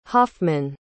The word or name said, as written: hoffman